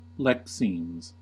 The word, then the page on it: lexemes: American English